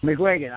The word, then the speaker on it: McGuigan: McGuigan. I want to make sure I said that right. I got JJ McGuigan on the line here, and we're going to meet JJ in a second.